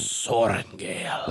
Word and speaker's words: Sorrengail: Is it a rolling R? It's like a Sorrengail. – Well, when I use the dramatic voice, it's Violet Sorrengail. – I think that's actually the correct pronunciation. I don't think... – Sorrengail. – Like, I think if you were talking to Violet, you were like, hello, Violet Sorrengail. She's like, it's Sorrengail.